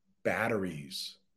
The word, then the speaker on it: batteries: Mustafa, can I please pronounce the word batteries? That's another one where it's a T, but we pronounce it with a D.